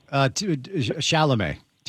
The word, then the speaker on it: Chalamet: How do you pronounce his name? – Chalamet. Timothy Chalamet. – Chalamet, yeah.